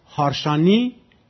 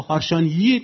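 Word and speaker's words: Harsanyi: مثلا فرض کنید حالا بلافاصله حالا به ذهنم بیاد، مثلا هارشانی نوشتن، این هارشانییه، این مجارستانیه، آخرش هم با وای ایه، خب هارشانیی تلفظ میشه دیگه، لزومی نداره که شما بدین هارشان.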